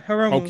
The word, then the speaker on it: Hiromu: So I think Hiromu, that's the way you pronounce his name, right? – Yeah, Hiromu.